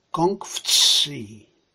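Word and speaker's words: Kong Fci (孔夫子): Kong Fci. Dosłownie tak się to wymawia po chińsku.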